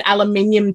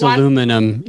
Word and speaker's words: aluminium: That means aluminum in the US. Aluminium is like a UK thing only. It's spelled completely different too. – Oh, okay. – Yeah, in the US, it is spelled to sound like aluminum. – Aluminum. – Aluminium has like an extra U in it, and it's only the UK.